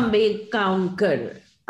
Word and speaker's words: Ambegaonkar: Um, how, do you pronounce that name? Ambeg. Mona Ambegaonkar.